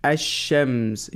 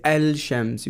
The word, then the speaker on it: الشمس: the lam is silent, and the word after, the letter after lam takes tashdeed. Ash-shams. You don't say al-shams, you say ash-shams.